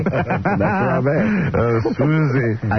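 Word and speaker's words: Swoosie: It's Swoozy. Rhymes with Lucy.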